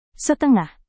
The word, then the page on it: setengah: Indonesian